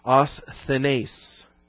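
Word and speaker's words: asthenes (ἀσθενής): The Greek adjective is asthenes, the same A-S-T-H-E-N, asthen, with the E-S ending. That's the long E, the eta, asthenes, number 772.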